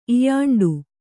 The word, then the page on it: ಇಯಾಂಡು: Kannada